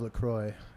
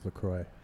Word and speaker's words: LaCroix: It is not LaCroix. That is how the French would say it.